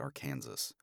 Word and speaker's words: Arkansas: Opinions on Arkansas, that it's definitely pronounced Arkansas.